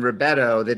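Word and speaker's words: Rubedo: You talk about the three steps being, and I'm going to mispronounce this, you talk about Negretto, Albedo, and Ribetto. – You did pretty good.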